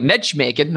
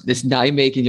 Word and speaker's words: Nijmegen: I remember the first thing you get in trouble for pronouncing wrong. Do you remember what it was? Nijmegen. And you were like, Nijmegen, they're playing at home. And it was like, who? And you're like, no, it's Nijmegen.